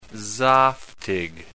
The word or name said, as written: zaftig